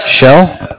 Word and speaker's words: Shell: Yeah, it's S-T-E-V-E. – S-T-E-V-E. And your last name? – Shell. Like seashell.